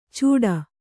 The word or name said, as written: ಚೂಡಾ